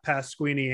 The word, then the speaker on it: Pasquini: You could find me on Twitter at Pasquini Andrew. My last name, P-A-S-Q-U-I-N-I.